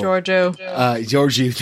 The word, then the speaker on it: Georgiou: Georgiou, thank you.